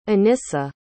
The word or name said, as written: anyssa